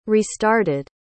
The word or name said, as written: restarted